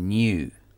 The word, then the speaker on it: knew: Knew s'écrit K-N-E-W. I knew. C'est la même prononciation que le mot new qui veut dire nouveau et qui s'écrit N-E-W sans K. La prononciation est la même.